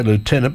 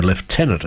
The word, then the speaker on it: Lieutenant: It's 25 past six, and Catherine Bell, who played Lieutenant Colonel, I say Lieutenant because that's how it's pronounced in America, we'd say Lieutenant in Australia, Lieutenant Colonel Sarah McKenzie is turning 58 today.